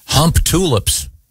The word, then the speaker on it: Humptulips: I wonder if they pronounce, maybe it's pronounced Humptalips. – Well, Humptalips is almost more worse. – Offensive. – Yeah. – I like it. – Yeah. But yeah, it's Humptulips.